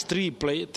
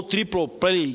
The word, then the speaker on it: triple play: não mais triplay, ou triple play, que é a pronúncia mais correta, mas em três fases, como se defendia muito mais aqui na casa, quando do surgimento da Lei Geral de Telecomunicações.